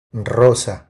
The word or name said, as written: Rosa